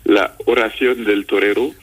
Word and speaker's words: La Oración del Torero: La Oración del Torero.